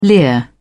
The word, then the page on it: leer: British English